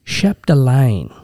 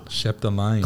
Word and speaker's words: Chapdelaine: Just darling, I've got to get this one right because it's a French pronunciation, the surname here. So it's Chloe Chapdelaine. – Chapdelaine.